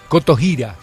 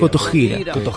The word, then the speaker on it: Kotohira: ¿Está bien pronunciado? – Kotohira sería. – Kotohira.